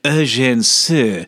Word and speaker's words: Sue: That's the English pronunciation. And if you'll forgive my bad French, Eugène Sue, for those who want to sound fancy about it or accurate.